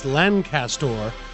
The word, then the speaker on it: Lancaster: I'm from Lancaster, which there is no G. It's Lancaster. But people who are from Lancaster prefer pronounce it Lancaster as if there is a G in there.